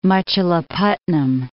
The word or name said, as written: Machilipatnam